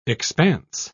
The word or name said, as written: expanse